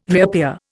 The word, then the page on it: reappear: Indian